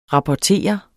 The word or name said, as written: rapportere